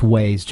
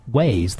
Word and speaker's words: Waze: Yeah, it's got to be pronounced Waze, John, because it's Waze, the ways to go.